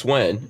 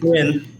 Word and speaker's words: Nguyen: So Nguyen. Well, I'm saying it wrong. It's Nguyen, right? – Nguyen. Yeah.